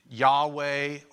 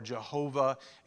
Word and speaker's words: Yahweh: Another way to pronounce it is Yahweh Jireh, because we're not quite for sure how to pronounce the name Yahweh or Jehovah. In ancient Hebrew letters, they didn't have vowels. They just had consonants. So they assumed how these things were pronounced. So Yahweh is spelled Y-H-W-H, but sometimes Y has a J sound, and sometimes W has a V sound. So that's where you could get Yahweh, or you could get Jehovah. And at some point in time, they started mixing in vowel letters, and they didn't like saying God's name.